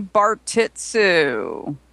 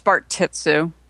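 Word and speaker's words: Bartitsu: We didn't directly call it Baritsu, or I don't know how to say it. – It's Bart Titsu.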